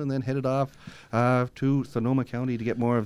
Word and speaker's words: Sonoma: Our guest today, talking about his trip to Sonoma County, not to be confused with Sonova County, which I constantly mispronounce it.